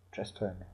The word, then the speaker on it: Chastain: Chastain. Ist das richtig ausgesprochen, ja, oder? – Ja.